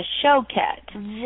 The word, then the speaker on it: Choquette: It's like Coquette with a show. It's a Choquette.